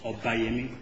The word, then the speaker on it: Obayemi: My first name is spelled O-L-U-M-I-D-E. The last name is O-B-A-Y-E-M-I. It's pronounced Obayemi, just like Obama.